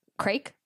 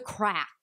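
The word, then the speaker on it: craic: Like, the wedding itself started at 1:30 and then crake. Do you know crake? Good fun is crake. – You're pronouncing it fucking wrong. Holy shit. Is it? It's the crack. Are you having the crack? It's C-R-A-I-C, the crake.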